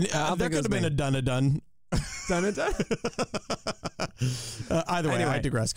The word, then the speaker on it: Dunedin: Someone yelled at me on my Twitter about mispronouncing Dunedin, and I was like, dog, that ain't me. I think I know who it was, even though you nailed it right there, of course.